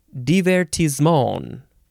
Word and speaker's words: divertissement: All right, the first word in this episode can be pronounced a few ways, but I think the most appropriate way, because it is French, is to say divertissement. Divertissement. You sort of say the N sound at the end, divertissement. It is spelled D-I-V-E-R-T-I-S-S-E-M-E-N-T.